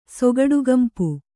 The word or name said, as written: ಸೊಗಡುಗಂಪು